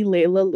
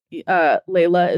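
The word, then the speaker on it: Leila: Okay, so I am on Twitter at only Layla Lewis. Layla is spelled LEILALEWIS. Nobody ever spells my name right. Only Layla Lewis.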